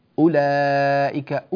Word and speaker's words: أولئك: لا ما فيش واو هنا, هذه الواو مكتوبة لكنها لا تنطق. أولئك أولى مباشرة.